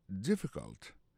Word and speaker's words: difficult: Is it difficult? Is it difficult? Difficult là, ça veut dire difficile. Lié plus comme ça, D-I-F-F-I-C-U-L-T. Difficult. N'a pas remarqué que l'accent tombé sur le premier syllabe là.